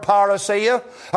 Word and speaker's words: parousia: the word "parousia," and here it is again in verse number 15, "we which are alive and remain unto the parousia."